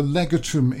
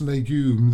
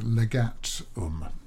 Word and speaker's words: Legatum: Almost. That's legume. This is L-E-G-A-T. More like legal, I think. Legatum. That's how it's spelled. It'd be Latin, wouldn't it? L-E-G.